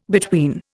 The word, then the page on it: between: Indian